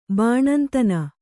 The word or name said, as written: ಬಾಣಂತನ